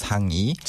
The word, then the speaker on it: 상의: 상의, but it's more naturally pronounced as 상의.